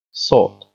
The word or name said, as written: sod